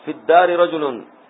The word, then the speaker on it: في الدار: وكذلك إذا كانت الكلمة السابقة آخرها حرف علة ساكن، هذا أيضا حذف آخر، الآن معنى الأل حذفت يبقى حرف آخر سيحذف الآن، فإنه يحذف نطقا لالتقاء الساكنين هذا الحرف الذي في آخر الكلمة، نحو في الدار رجل فينطق بها هكذا في الدار رجل، يعني كأنها فاء ودال فقط،